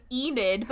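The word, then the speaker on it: Ynid: The dolphin winks at her, and we learn that its name is Enid, her name is Enid. It's spelled Y-N-I-D. I had to write it E-N-I-D to make sure that I'd remember how to pronounce it.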